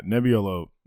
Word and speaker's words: Nebbiolo: This is the Nebbiolo. Nebbiolo grape. And it's been around since like the 1200s. – Yeah, Nebbiolo is really good.